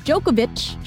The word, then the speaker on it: Djokovic: And like Novak Djokovic. The C at the end. – Uh-huh. – Is a ch. – Yeah. – Because they're Serbian.